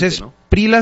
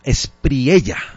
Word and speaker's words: Espriella: Es Esprila, ¿no? – Sino Espriella.